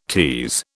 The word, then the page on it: tease: British English